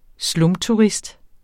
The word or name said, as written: slumturist